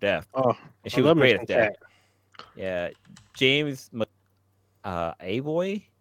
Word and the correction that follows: McAvoy: Is that how you pronounce the name? – Oh, he McAvoy? Oh, yeah, James McAvoy.